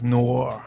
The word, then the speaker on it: noir: You need two syllables. That's probably what's throwing you off. – Yeah, it is noir.